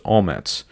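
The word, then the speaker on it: omets (אומץ): And then the Hebrew word for courage is pronounced omets.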